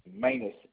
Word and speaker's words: Manus: Manus. M-A-N-U-S.